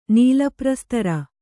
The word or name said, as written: ನೀಲಪ್ರಸ್ತರ